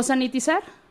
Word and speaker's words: sanitizar: ella lleva su líquido para satinizar, sí se dice así, ¿verdad? Eh, o sanitizar. Ay, bueno, así. Perdón, sí, sentí que lo dije mal.